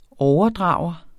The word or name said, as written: overdrager